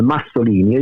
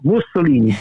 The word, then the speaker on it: Mussolini: il nostro insegnante di storia per cui pronunciava la Mussolini come Massolini, e io dicevo no, Mussolini Praga perché era,